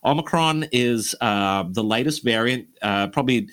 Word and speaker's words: Omicron: Questions like, how does one pronounce Omicron? – Omicron. Thank you. – You have got it right there.